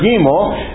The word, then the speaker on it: gimel: Now I know how it's spelled, but sources I have say that's spelled like a long E, gimel.